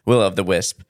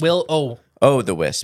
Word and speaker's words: will-o'-the-wisp: Well, if you would like to have an in-depth episode on the will-o'-wisp, will-of-the-wisp, is that how you say it? Will-of-the-wisp? – Will-o-the-wisp. Just O.